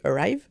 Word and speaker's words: ARRIVE: Parce que cette étude, donc, qui s'appelle, donc moi je le prononce Arrive, on aime bien prononcer en français, mais donc on dit Arrive.